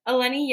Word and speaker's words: Eleni: Eleni Genagritis.